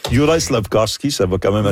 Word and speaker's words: Juraj: Personnellement, moi, j'ai de la difficulté avec Jurav Slavkovski. – Juraj.